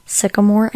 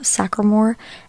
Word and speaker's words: Sycamore: It is actually pronounced Sycamore and not Sacramore.